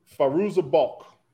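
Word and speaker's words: Fairuza Balk: Faruza Balk.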